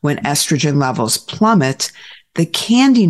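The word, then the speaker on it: KNDy: but commonly called the candy neurons. It's pronounced like the chocolate stuff, but it's spelled with a K. K-N-D-Y.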